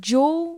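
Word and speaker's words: Zhou: Ben, ça, le nom de famille se prononce «jo».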